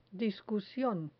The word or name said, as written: discusion